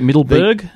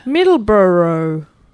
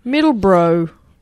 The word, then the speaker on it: Middleborough: Do you get Middleburg? – Middleborough. – Really? – Middleborough.